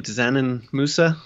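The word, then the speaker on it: Džanan Musa: And then in addition to Kurucs, who is possibly going to miss the start of the season, you've got, I don't even know how to pronounce his name, Dzanin Musa. Say that right? – Yeah, close enough.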